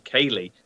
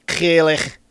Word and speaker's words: Ceilidh: I love the fact that you pronounced it lovely, Kaylee, because earlier on when you sent that text, it auto-corrected to Ceiling Lagerdale. – Oh, did I? Didn't even notice it because Ceilidh, Kaylee, Kaylee.